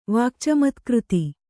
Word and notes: ವಾಕ್ಚಮತ್ಕøತಿ: Kannada

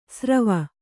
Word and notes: ಸ್ರವ: srava

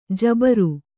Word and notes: ಜಿಬರು: jibaru

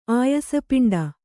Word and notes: ಆಯಸಪಿಂಡ: Kannada

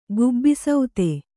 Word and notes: ಗುಬ್ಬಿಸೌತೆ: Kannada

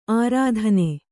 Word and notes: ಅರತ್ನಿ: kannada

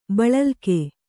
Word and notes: ಬಳಲ್ಕೆ: baḷalke

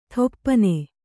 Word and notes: ಥೊಪ್ಪನೆ: Kannada

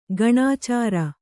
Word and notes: ಗಣಾಚಾರಿ: gaṇācāri

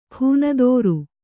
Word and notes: ಖೂನದೋರು: khūnadōru

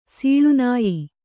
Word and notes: ಸೀಳ್ನಾಯ್: sīḷnāy

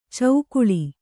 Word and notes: ಚೌಕುಳಿ: caukuḷi

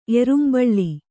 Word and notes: ಏರುಂಬಳ್ಳಿ: ērumbaḷḷi

